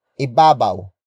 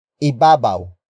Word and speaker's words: ibabaw: It is the focus marker that introduces the main topic of this sentence, which in this case is a phrase starting with ibabaw. Ibabaw. Ibabaw. Ibabaw is spelled as I-B-A-B-A-W.